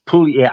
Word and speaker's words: Puglia: um the t- it, it's a it's Puglia. I, I trust me, I I can yeah, you butcher words. No, I'm just helping you. You don't pronounce the G in Italian. It's, I don't. We never. We don't even know why it's there. – Okay. yeah, it's just like Puglia.